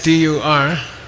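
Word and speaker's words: dur (བསྡུ): So B S D U R is pronounced dur. – Esa palabra se pronuncia dur. – The B is silent and the S is silent.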